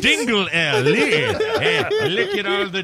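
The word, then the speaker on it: Dingle-L: Dingle L? – That's how it's spelled. Timothy Dingle hyphen L. So I guess it's Dingle L.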